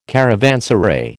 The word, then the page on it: caravanserai: American English